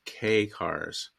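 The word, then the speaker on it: kei: And key in Japanese is pronounced K cars. – Oh, is it really? Oh, that's what happens when you read words and you don't hear them pronounced. – You know, in Japan, they had a different pronunciation. It wasn't K. It was like somewhere between K and key. It was like K. Cars, I don't know.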